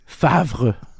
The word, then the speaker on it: Favre: I mean, Brett Favre is like just a total mind fuck for a French Canadian child to be like, what do you mean Favre? – What is it? – Favre. But like it's like the R doesn't even come before the V.